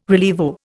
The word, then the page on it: rilievo: Indian